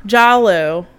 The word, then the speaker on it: Giallo: And then it just be, it, it gets super crazy, and yeah, it gets very, and I cannot pronounce the damn word, Giallo. – It's Italian for yellow, so just imagine it sounding like the word yellow, because that's what it is. Like, Giallo.